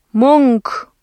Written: мөнгө

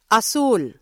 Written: asul

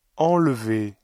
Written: enlever

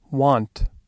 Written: want

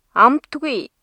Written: амтгүй

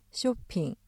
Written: 쇼핑

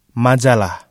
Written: majalah

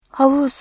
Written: havuz

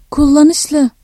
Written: kullanışlı

